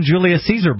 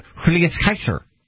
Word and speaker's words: Julius Caesar: They would not have called him Julius Caesar because the J's were pronounced like an H sound, so he would have been Julius Kaiser.